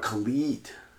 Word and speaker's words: Khalid: Were Khaled songs. – Location and Young, Dumb, and Bro. – Oh, you're talking about Khalid.